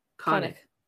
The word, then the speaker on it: Cannock: C-A-N-N-O-C-K. – Kanak? – Kanak.